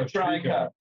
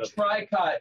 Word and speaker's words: Tricot: It's called Tricot, and you know how to spell that? Because Tricot is T-R-I-C-O-T, and that's how you spell Tricot, and that's how you say Tricot.